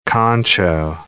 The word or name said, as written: concho